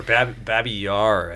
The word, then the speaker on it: Babi Yar: Baba Yaga, I think it is, or something like that, is how it's pronounced. – Or Babi Yar.